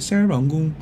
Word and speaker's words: Serangoon: Serangoon, say it like a local.